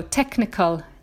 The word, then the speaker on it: technical: They like silent letters. Here the H is silent. I've got a list of those. If you followed my pronunciation Advent Adventure at Christmas time, you know, all of these words, they are quite easy to predict the kind of words that they test. So technical, 37, like chemistry and ache, all of those words with the CH pronounced C.